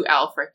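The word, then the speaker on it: Ælfric: Okay, and contextualize who Alfric is for our listeners. – Alfric, that's spelled Ash, L-F-R-I-C,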